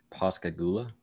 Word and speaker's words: Pascagoula: I just want you to go to your Google and type in PASCAGOULA. – Pascagoula? – Huh? – Pascagoula? – Close. Pascagoula. – Pascagoula. – Oh, Pascagoula.